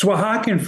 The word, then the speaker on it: Oaxacan: Because Oaxacan food, like you said, I think the X is with an H or pronounced like an H, like Oaxacan food. The when you say Oaxacan, the ha, that's actually an X.